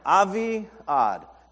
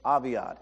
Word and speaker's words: Aviad: I clicked that microphone to make sure I didn't mispronounce it, and I was like, this was actually the easiest word. There's like no to it or any. It's just Aviad. That's it. Aviad.